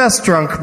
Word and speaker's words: Festrunk: The Festrunk brothers. – Okay, all right. That's the Bohemian pronunciation.